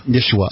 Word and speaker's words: Yeshua: Jesus' name is like Yaheshua, and I'm probably mispronouncing it. – Yeshua.